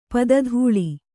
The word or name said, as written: ಪದತಳ